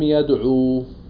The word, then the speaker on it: يدعوا: الألف الفارقة هي ألف زائدة خطا لا لفظا ما بتلفظها بس بتخطها بعد واو الجماعة في نحو قولك القوم لم يدعوا